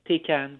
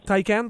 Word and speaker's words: Taycan: Et là, je suis pas sûr de la prononciation. Tu me pardonneras, Louis-Philippe. T-A-Y-C-A-N. Taycan. Qu'est-ce que tu en penses? – Taycan, probablement. – Oui, Taycan. Ouais. Taycan. Oui, c'est bon.